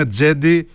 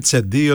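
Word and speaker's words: Cedi: Γι' αυτό και το όνομά του προφέρεται ας πούμε Τζέντι και όχι Τσεντί, οτιδήποτε το λέγανε στην αρχή, Τζέντι Όσμαν.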